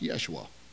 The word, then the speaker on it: Yeshua: It was Aramaic. It was actually pronounced Yeshua.